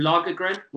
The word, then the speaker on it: Lagergren: Yeah, and Gus, so Gus, you're with Joakim Lagergren. Once again, I've pronounced that kind of right. – You're pretty good, yeah.